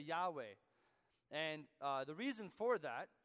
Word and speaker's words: Yahweh: The LSB translates it Yahweh, because we have good reason to believe that's the correct pronunciation.